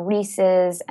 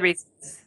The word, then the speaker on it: Reese's: which is, that's how it's pronounced. I've been told, I know that there's a big debate between Reese's and Reese's. – Oh no, it's Reese's. It's totally Reese's.